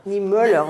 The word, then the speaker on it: Niemöller: Moi, je ne prononce pas bien l'allemand. Toi, tu prononces mieux l'allemand. Niemöller, voilà. Heureusement que tu es là.